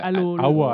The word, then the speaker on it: Alola: C'est censé se passer dans un monde qui s'appelle Aloha. Donc, Alola, qui se prononce Alola.